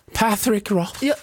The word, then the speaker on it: Patrick Rothfuss: Patrick Rothfuss. – Jag tyckte det var perfekt. – Ja. Så hade han uttalat det själv.